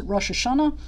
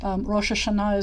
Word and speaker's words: Rosh Hashanah: Some people may pronounce it Rosh Hashanah. That has to do with where your linguistic background is. If you're from Eastern Europe and have a Yiddish background, you might say Rosh Hashanah. And most Jews in America come from a background of Eastern Europe, so you may hear that pronounced that way. Rosh Hashanah is the modern pronunciation, a Sephardic pronunciation, and an Israeli pronunciation, or we'll talk about languages another time.